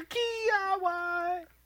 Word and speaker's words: Kvyat: I guess it's Daniel or similar to Dan. Daniil. And then KVY. – Daniil Kiyawai. – Yes, that's it right there.